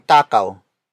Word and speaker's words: takaw: Word for word, it's kasing takaw ni Robert si Paolo.